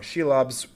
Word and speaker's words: Shelob: Came in very useful for the scenes where the comatose Frodo was wrapped up in, I always say this wrong, Shelob's web-like cocoon.